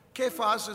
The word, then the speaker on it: Kephas: Kephas is the Hebrew pronunciation of the name that Jesus actually gave to Peter, which is a stone.